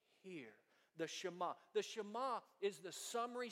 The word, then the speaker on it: Shema: It's a passage known as the Shema. Say that with me. Shema. Say it like you mean it. Shema. S-H-E-M-A.